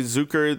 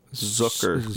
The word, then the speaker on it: Zucker: Jerry Zucker. This has been. Did I say it right? No. Z- Keep trying. Zucker. All right. Book. Remember. – Zucker. – Yes.